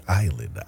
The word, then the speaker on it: island: Your Go Anywhere Getaway keyword is just one of those words that doesn't make sense in the way it's spelled, but it's island, I-S-L-A-N-D, island.